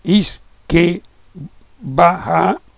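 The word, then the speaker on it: uisce beatha: Uisce beata, che si pronuncia isce baha,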